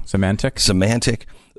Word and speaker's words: Symantec: Now, the agencies utilized intelligence gathered by Symantec. Is that how you say it? – I think so. Symantec.